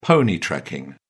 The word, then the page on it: pony-trekking: British English